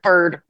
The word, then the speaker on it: Byrd: And that process of review is sometimes referred to sort of cheekily on Capitol Hill as the bird bath, because it's the bird rule. It's spelled B-Y-R-D, though, so you got to be a little careful.